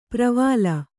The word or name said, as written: ಪರಿವೇಲ